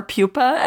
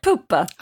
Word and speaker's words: Pupa: Well, it's an Italian haunted doll, so I think it's like Poopa. – Ah, got it. – Like, it's spelled P-U-P-A.